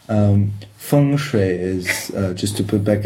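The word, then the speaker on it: feng shui (风水): Okay, I think most Westerners pronounce it feng shui or feng shui. – Feng shui. – Yes. – And Chinese it would be feng shui.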